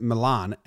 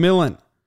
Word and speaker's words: Milan: It looks like it's pronounced Milan, M-I-L-A-N, but it's pronounced Millen.